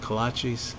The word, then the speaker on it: kolaches: Amongst transactions, picking out donuts and is it kolaches? – Yeah, you got it.